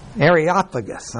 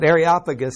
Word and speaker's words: Areopagus: Areopagus. – I knew there was a good way to do that. Areopagus, saying, may we know what this new doctrine whereof thou speakest is.